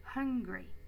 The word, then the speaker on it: hungry: Make sure to pronounce the H at the beginning, hungry.